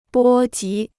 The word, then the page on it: 波及: bō jí